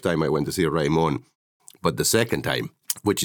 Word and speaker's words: Raimon: Is that pronounced okay? – It's perfectly pronounced, Raimon.